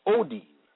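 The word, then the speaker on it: ōdin: The Greek word for sorrows is Odin, pronounced Odin, meaning pangs or through a hard or painful struggle, especially of childbirth.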